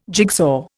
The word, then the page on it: jigsaw: Indian